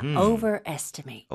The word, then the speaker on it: overestimate: Overestimate. – Overestimate. 오케이. 둘다 동사이기 때문에 much 아니라 mate로 발음하셔야 돼요.